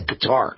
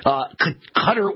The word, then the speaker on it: Qatar: I believe that's how you pronounce the country, right? It's Qatar. Or maybe it's Qatar. Maybe it's Qatar. It's Qatar. I'm sorry. It looks like Qatar, but it's Qatar.